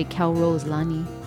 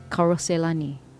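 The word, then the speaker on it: Calrose Lani: It doesn't sound right if you say Cal Rose Lani. Yeah? You gotta say Karose Lani.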